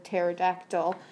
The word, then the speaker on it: pterodactyl: I'm not looking at the answer, but I'm looking at the word pterodactyl. And um, the answer to that question is because it has a silent P.